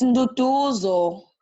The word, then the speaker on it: Duduzo: I get strange called, like, they call me like Induduzo, Eduardo, Duda.